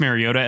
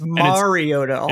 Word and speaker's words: Mariota: I really view Marcus Mariota as like a Tyrod Taylor or a Tyrod Taylor, I guess is how the correct way to say it. But it's Mariota.